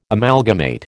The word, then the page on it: Amalgamate: American English